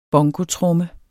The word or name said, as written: bongotromme